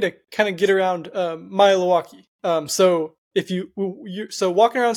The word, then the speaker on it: Maealiuaki: It's easy if you kind of break it up into three kind of syllables and say it fast. – Maealiuaki. That's it? – That's it. Maealiuaki.